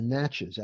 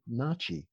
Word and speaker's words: Natchez: And in researching them, I found that there was a tribe called the Natchez. Actually, they pronounce it Nachi.